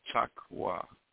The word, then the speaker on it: taqwa: And the transliteration is spelled T-A-Q-W-A. Pronounced taqwa. And of course, those who speak Arabic, they know that the ka sound has a particular click type sound to it, taqwa, which is Arabic for God consciousness.